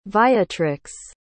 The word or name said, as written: viatrix